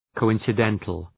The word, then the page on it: coincidental: kəʋ,ınsı’dentəl